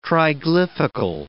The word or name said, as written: triglyphical